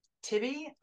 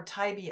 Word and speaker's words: Tybee: It's happening on, I've heard people pronounce it Tybee Island or Tybee Island, T-Y-B-E-E.